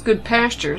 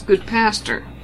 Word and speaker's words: Goodpasture: Now, it's not pronounced Good Pasture. It's Good Pastor. – Yeah, that's the main name of it. – And it was named after Billy Goodpasture. – Billy Goodpasture. How do you spell that last name? – Goodpaster. It's not like a pasture.